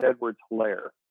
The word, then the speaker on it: Edwards-Hilaire: I believe it's Clyde Edwards-Hilaire. But, like hilarious? – Mm-hmm, there you go.